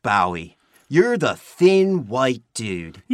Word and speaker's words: Bowie: You're pronouncing his name wrong. It's Bowie, not Bowie. – In England, they pronounce Bowie, Bowie. I thought Canada was part of England. – Well, it's not. And even if it was, David Bowie, Bowie, is not from Canada.